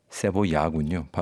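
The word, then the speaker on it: cebolla: 1930년대부터 50년대까지의 그 멕시코 시티 중심으로 한창이었던 볼레로, 카바레, 어구스틴 라라의 곡들 뭐 이런 것들을 이야기하면서도 자신의 나라 칠레의 그 무시카 카베 세볼라, 무시카 세볼라, 세보야군요. 발음이 세보야 같은 이런 신파 발라드 그리고 엔카.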